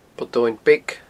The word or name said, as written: Poll Domhainn Beag